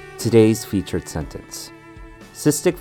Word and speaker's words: cystic: For the first word, we have cystic, two syllables. The first syllable, cis, sounds more like it's spelt S I S with a short I sound. Cis. The second syllable, tick, has a short I sound as well. Tick, cystic. Next up, we have fibrosis.